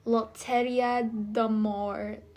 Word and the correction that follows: Loterie d'Amour: Let's see how you pronounce that. Loteria. Let's see. Pronunciation. How do you pronounce? Oh, d'Amour. There you go. Oh, look at me, French. Loterie d'Amour, in which hopeful singles would line.